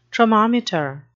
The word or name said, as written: tromometer